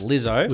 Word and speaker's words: Lizzo: Lizzo, that's pronounced Lizzo. – With an E.